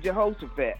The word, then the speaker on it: Jehoshaphat: Is it in Jehoshaphat? Is that how you say it? – Yeah, the Valley of Jehoshaphat.